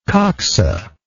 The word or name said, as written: coxal